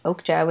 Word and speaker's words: Okja: I think you're pronouncing it right. – Okay, I heard somebody in like the Korean film cinema world say it as Okja the other day,